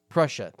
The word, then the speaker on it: Prussia: Or Persia, I can't pronounce it. – Prussia? – Prussia, thank you.